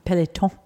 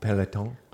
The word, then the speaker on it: Peloton: A Peloton. – Am I saying that right? – Peloton. – Peloton.